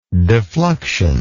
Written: defluxion

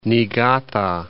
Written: Niigata

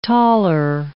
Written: taler

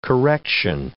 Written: correction

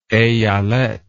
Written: eyalet

